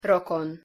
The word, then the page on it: rokon: Hungarian